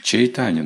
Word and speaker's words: Чайтанья: Чайтанья нужно говорить. Произносится Чайтанья.